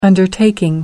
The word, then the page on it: undertaking: American English